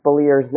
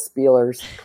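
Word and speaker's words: Speleers: Ed Spaliers, and it is pronounced Spaliers, not Spielers.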